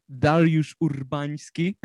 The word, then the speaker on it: Dariusz Urbański: Now, I'm curious about the features that you have on here, because you've got Darius Urbanski. Is that how you say it? – those are hard names to spell for you, probably. Dariusz Urbański and Bartosz Jankowski. – You were way off.